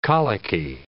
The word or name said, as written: colicky